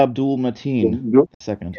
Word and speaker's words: Abdul-Mateen II: Maya, whatever the second. – Yahya Abdul-Mateen second. – Yeah, Abdul-Mateen. There you go.